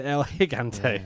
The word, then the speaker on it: El Gigante: Correction. It's El Gigante. – Is it El Gigante? – Yeah, that's how he gets pronounced.